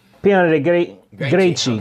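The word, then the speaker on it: Piana dei Greci: Mussolini visited Sicily in 1924, and he passed through this one little town of Piana de Greci. Would that be how you pronounce that? G-R-E-C-I? – Piana de Greci. Okay. It'd be like a C-H.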